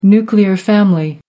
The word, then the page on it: nuclear family: American English